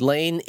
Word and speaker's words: Leine: And for people who are listening, Lane is spelled L-E-I-N-E. And I had the worst time with that. And you know, in the first book, you go into some detail about how it's pronounced, but I couldn't bring myself to pronounce it that way.